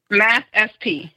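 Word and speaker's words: MathSP: I should have asked you, is it Mathspy? Almost like your name? Is that the way it's pronounced? – Mathspy. The SP stands for Strategies and Problem Solving. – Yeah, that's almost like, because your name is pronounced SP. So Mathspy.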